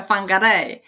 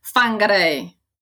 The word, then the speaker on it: Whangarei: Tak się to wymawia, Whangarei. A niektórzy ludzie powiedzieliby to Whangarei. Ponieważ tam mamy wy i hy razem, to tutaj jest fy, prawda? W angielskim. Ale po maorysku jest Whangarei.